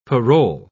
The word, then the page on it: parol: British English